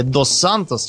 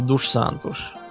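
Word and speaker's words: dos Santos: Ну, как у, у, у этих, у, э, у, у испанцев у них фамилия «Дос Сантос» читается, mm-hmm. – а у португальцев «Душ Сантуш».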